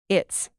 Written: its

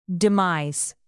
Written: demise

